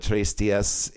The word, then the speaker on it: Tres Dias: And if you want to pronounce that the right way, then you would call it Tres Dias. It's the Spanish phrase for three days, is what it means.